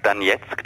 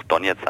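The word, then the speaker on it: Donezk: Genau, also nicht wundern, ich sag Donetsk, das ist die russische Aussprache, da ich selber Russisch spreche, habe ich mir das so angewöhnt, aber man kann natürlich in Deutschland auch Donetsk sagen mit dem betonten O, dass es da zu keinen Verwechslung kommt.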